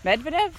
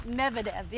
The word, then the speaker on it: Medvedev: I really, when I said it out loud, I went, is that really how you say it? – No, it's Medvedev. – Medvedev. – Yeah, M-E-D-V-E-V. Like, he's got too many Bevs in there.